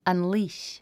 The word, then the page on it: unleash: ʌ̀nlíːʃ; アンリィッシュ